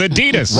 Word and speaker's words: Adidas: Yeah, but I hear people from England saying Adidas, and I'm like, what are you talking about? – Right. – It's Adidas. – Right. No, they say it right. We say it wrong.